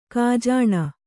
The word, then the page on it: ಗಜನಿ: kannada